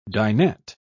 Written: dinette

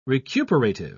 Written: recuperative

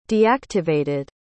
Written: deactivated

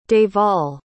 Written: Devall

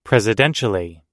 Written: presidentially

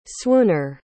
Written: swooner

